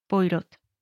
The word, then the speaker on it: Poirot: Además, también por cosas de la propia historia, que ahorita van a ver, conviene pronunciarlo tal cual como se lee Poirot.